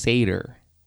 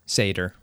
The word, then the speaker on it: Sator: I said the title for the movie was Sator. – Mm-hmm. – But it's actually pronounced Sator. – Sator. – Which I would have never known. – Ever. Yeah, well, I mean... – How would you know? – Well, how do you spell gator?